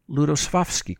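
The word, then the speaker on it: Lutosławski: We'll hear the first movement, the first 5 minutes or so of the first movement, played by the Ludosławski Quartet, or if I can attempt a Polish pronunciation, Ludosławski Quartet.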